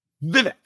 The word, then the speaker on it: Vivek: Vivek. – Yeah, Vivek. Vivek like Trebek. Yeah, that's what he says. Yeah, Vivek. – "I'll take the penis mightier, Trebek." It's like that. "I'll take the penis mightier, Vivek."